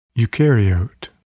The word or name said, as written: eucaryote